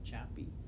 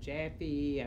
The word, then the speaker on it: Joppa: Well, on paper, it is spelled J-O-P-P-A, and so phonetically, one would call it Joppa, but the locals and people who have been in the community for generations pronounce it in various ways, and so one of the ways that, one of the pronunciations that has stuck is Joppy. I've heard Jaffy, I've heard... the spellings have been different, J-O-P-P-E-E, J-O-P-P-Y, J-O-P-P-A is the spelling that is on paper, on maps okay.